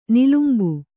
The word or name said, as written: ನಿಲುಂಬು